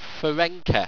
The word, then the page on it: farenqa: Owstwecian